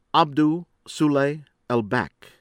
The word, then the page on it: Abdou Soule Elbak: American English